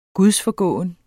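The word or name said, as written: gudsforgående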